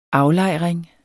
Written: AFLEJRINGERS

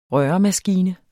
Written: røremaskine

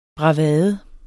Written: bravade

